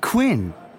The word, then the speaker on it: Quinn: J-A-C-K-M-O-O-R-E. – 2. – Good afternoon. I'm Dieter Quinn. – How do you spell that? – D-I-E-T-E-R-Q-U-I-N-N.